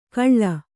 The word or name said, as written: ಕಳ್ಳ